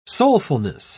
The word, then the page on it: soulfulness: British English